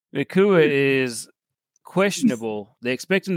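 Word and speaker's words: Nacua: Nakua.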